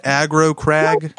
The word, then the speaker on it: Aggro Crag: Am I saying it right? Aggro crag? – Yes. Yeah.